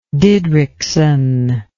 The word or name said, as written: Didrikson